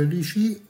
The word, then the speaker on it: litchi: Sempre come frutta troviamo tra le primizie il lici, si scrive L-I-T-C-H-I, si pronuncia lici,